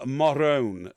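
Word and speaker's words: Morrone: Her name is Camilla Morone, okay? It's an unfortunate name, isn't it? It's spelled like moron, but it's, it's Morone.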